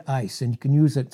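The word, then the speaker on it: ice (εἰς): It's ice. It's pronounced like our word ice, I-C-E, but it's spelled E-I-S in the Greek, and this is what it means.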